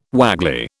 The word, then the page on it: waggly: British English